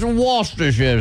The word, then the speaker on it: Worcestershire: It's Worcestershire. It's Worcestershire. – Worcestershire. – Worcestershire. Worcestershire sauce. That's how they say you're supposed to pronounce it.